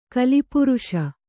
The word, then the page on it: ಕಾಲಪುರುಷ: kālapuruṣa